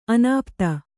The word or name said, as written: ಅನಾಪ್ತ